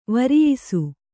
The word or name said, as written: ವರಿಯಿಸು